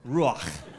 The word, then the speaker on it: Ruach: In the Old Testament, it's this word, Ruach. You gotta say it like you have popcorn in the back of your throat. Ruach.